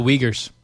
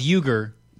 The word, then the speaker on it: Uyghur: The Uyghurs. – Is it? – We were corrected. It's pronounced Uyghur. – Oh, I apologize. – It's spelled Uyghur.